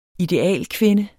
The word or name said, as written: idealkvinde